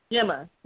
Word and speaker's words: Gemma: Gemma Chan, and she's wearing Valentino.